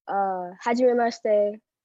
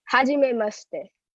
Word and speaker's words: Hajimemashite: Am I saying it right? Hajimemashite? – Hajimemashite. – Hajimemashite. Is that right? I'm saying two different things. I know I am. – Haji. – Haji. – Me. – Me. – Maste.